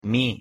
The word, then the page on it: mi: Spanish